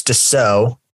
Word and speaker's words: Dassault: If you're reading the title, it probably looks like Dassault Systèmes. It's Dassault is the proper pronunciation.